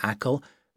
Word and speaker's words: Achill: You say Achill, but it's spelt A-C-H-I-L-L.